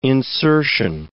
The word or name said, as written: insertion